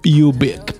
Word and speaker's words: Ubik: Dipende dalla pronuncia. Come lo pronunci? – Ubik, perché mi sembra molto più futuristico.